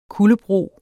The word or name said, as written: kuldebro